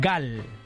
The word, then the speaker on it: GAL: no se debería llamar GAU, con U, que es como se llama las grasas en Brasil, sino GAL, que en portugués de Brasil se pronuncia igual,